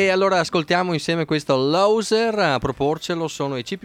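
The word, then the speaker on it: Low-Sir: Allora, adesso facciamo un pezzo che si intitola Loser, scritto però non all'inglese, scritto Low, L-O-W, trattino, Sir. Che si legge Loser.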